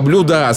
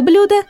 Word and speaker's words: блюда: Запомните, ребята, в формах множественного числа существительных «благо», «блюдо», «средство» ударение не ставится на последний слог. Так произношение «блага», «блюда», «средства» является грубейшей ошибкой и свидетельствует о низкой культуре говорящего. – Правильно же следует произносить эти слова с ударением на первый слог. «Все блага», «ни за какие блага мира», «красивое блюдо», «вторые блюда», «выделенные средства».